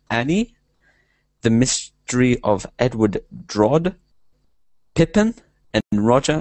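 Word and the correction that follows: Rodgers: and Hammerstein's Cinderella. Hopefully I said that right. – Rogers and Hammerstein. – Hammerstein, sorry.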